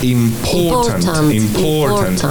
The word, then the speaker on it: important: Important. No vamos a pronunciar la R. Va a pasar igual que con confortable, con comfortable. No pronunciamos ni la O ni la R del medio en importante.